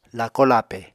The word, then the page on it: lako-lape: Apatani